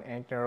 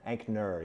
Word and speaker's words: Anker: Anker, yeah. Anker. You got it. – There we go. Perfect. They, people confuse it with the cell phone chargers, Anker.